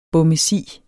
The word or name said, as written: bommesi